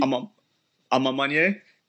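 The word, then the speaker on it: A Ma Maniére: I like, I always like how you pronounce the French word that I always struggle with. So it's the Ama Manerie. – Ama Manier.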